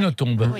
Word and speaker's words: Nothomb: Vous prononcez le B à la fin. – Le B se prononce, oui. – Oui, on dit Nothomb, effectivement. – On dit Nothomb.